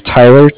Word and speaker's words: Tyler: My name is Tyler, T-Y-L-E-R, and like I said, I'm calling with Cardiff. It's spelled C-A-R-D-I-F-F, as in Frank Frank.